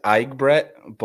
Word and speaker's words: Egbert: So the right way to say it is Egbert, but I never correct people just because it can be kind of hard. But Egbert, however you feel comfortable, whatever's easy, whatever rolls off the tongue, just go.